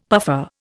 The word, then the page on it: puffer: Indian